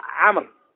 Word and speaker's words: عمرو: هذه الواو زائدة تكتب ولا تلفظ إنما جاء بها من أجل التفريق بين عمر وعمر فإذا رأينا مثل هذا ولو لم يكن مشكل بالتشكيل نقرأ عمر ما نقول عمر لأن عمر ما